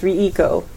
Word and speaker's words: Reiko: Yeah, it's Riko. Sorry, Riko is how it's pronounced, they say. – It's not Riko. – No, it's Riko. – R-E-I-K-O.